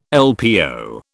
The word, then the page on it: LPO: British English